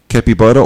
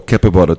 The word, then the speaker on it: capybara: Okay, there's two ways of saying it. – What? – Capybara or capybara. Depends if it's Americanized or British.